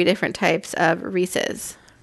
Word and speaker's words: Reese's: It's definitely Reese's, not Reese's. There's no way, there's no world in which that would be pronounced Reese's. – Unless you're Southern, I think. – Southern? – Yeah. I think they call it Reese's. – A lot of people do, and they're wrong.